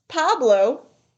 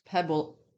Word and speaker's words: Pueblo: It's not Pablo. What is it? – It's Pueblo. That's what it is. – It's like a pebble now.